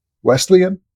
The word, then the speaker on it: Wesleyan: In Indiana, Wesleyan, is that how you say it? – Yep.